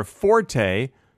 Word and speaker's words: forte: I want to see how to pronounce F-O-R-T-E. There is some controversy over how to correctly pronounce fort. Common choices in American English are forte and forte, but many usage commentators recommend matching it to fort since the E is not pronounced in French.